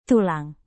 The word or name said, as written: tulang